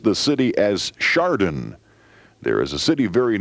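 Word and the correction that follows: Chadron: Thanks to Bob McCoy, who dropped a postcard to remind me that in Nebraska, it's C-H-A-D-R-O-N, and it is pronounced Shadron.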